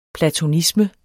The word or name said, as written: platonisme